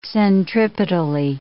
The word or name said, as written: centripetally